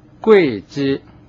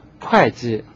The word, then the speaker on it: 会稽: 读音我在这说明一下，就是会稽郡，这个会稽的会，有的词典上它注的音是贵，这样就读成贵稽郡。那么究竟哪一种读音最好呢，最合理呢？我个人认为，如果读贵稽，那是照顾了方音，如果按照普通话的要求读成会稽是合适的，所以下面我读的时候我还是把它读成会稽。